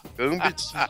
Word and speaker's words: Gambit: Gambit. Não, tá, tá certo, mas essa pronúncia aí. – Sensual. Mordeu o lábio pra falar. Porque é francês, porra, e tem que fazer biquinho.